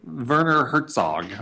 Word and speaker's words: Werner Herzog: Okay, so filmmaker Werner Herzog, I'm not sure I'm pronouncing that correctly. – Yep, that's correct, pretty good.